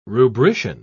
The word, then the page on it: rubrician: British English